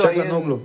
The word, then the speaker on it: Çalhanoğlu: Çalhanoğlu, ¿se pronuncia así? Vos que sos un experto. – Çalhanoğlu, sí. Así se pronuncia.